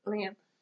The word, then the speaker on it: Lyanna: Would you rather have Lyanna, is that how you say it? – Lyanna.